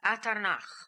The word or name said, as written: Atarnakh